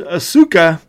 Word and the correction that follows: Asuka: just reading it how it looks. And I'm like, oh, wait a minute, that's not right. It's Asuka versus Rhea Ripley.